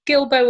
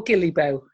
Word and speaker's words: Guillebeau: It, it looks like it should be pronounced differently. So I'm glad I did look at the videos. So Gilbo or Gillibo seems to be the way to pronounce it.